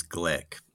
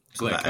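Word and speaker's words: Glück: And Louise, is it Gluck? Is that, I never know how to say her name. – I've just heard recently from others, Glick. – Glick.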